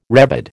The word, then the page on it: rebid: American English